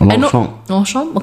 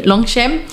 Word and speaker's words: Longchamp: Longchamp. – Okay, Longchamp.